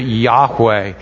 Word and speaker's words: Yahweh: We pronounce it Yahweh.